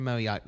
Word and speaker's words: Moyock: I saw one from Moyock this morning. – You did. You pronounced it right, too.